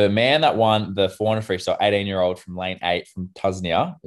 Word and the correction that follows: Tunisia: Is that how you say it? Tuznia? – I like to go with Tunisia, but if you want to do Tuznia, you can do Tuznia. It's Tunisia, mate. Come on.